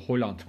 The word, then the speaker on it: Haaland: Bu arada Haaland değilmiş o. Holland. Holland O üzerindeki noktalar Holland mı? Holland okunuyormuş.